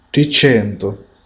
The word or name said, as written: 300